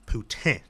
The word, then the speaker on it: poutine: And honestly, I was in Montreal over the New Year's, and I finally had their poutine. Did I say that right? – Poutine.